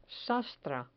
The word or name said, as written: sastra